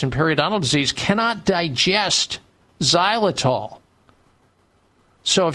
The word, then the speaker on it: xylitol: and mints are not rich in xylitol. Xylitol is spelled X-Y-L-I-T-O-L.